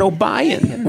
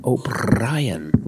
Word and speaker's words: O'Brien: It's Conan O'Brien. Roll that R.